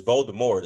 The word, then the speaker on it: Voldemort: It's Voldemort. The T is silent.